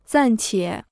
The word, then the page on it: 暂且: zàn qiě